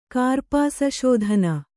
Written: ಕಾರ್ಪಾಸಶೋಧನ

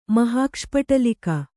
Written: ಮಹಾಕ್ಷಪಟಲಿಕ

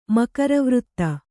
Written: ಮಕರವೃತ್ತ

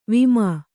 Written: ವಿಮೆ

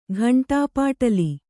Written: ಘಂಟಾಪಾಟಲಿ

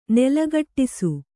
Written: ನೆಲಗಟ್ಟಿಸು

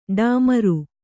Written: ಡಾಮರ

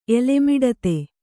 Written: ಎಲೆಮಿಡತೆ

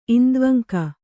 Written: ಇಂದ್ವಂಕ

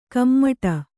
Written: ಕುಮುಟು

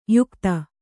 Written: ಯುಕುತಿ

